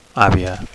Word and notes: avia: Catalan

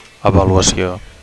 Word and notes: avaluacio: Catalan

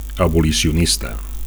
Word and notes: abolicionista: Catalan

